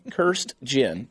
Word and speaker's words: Kirstjen: Kirstjen.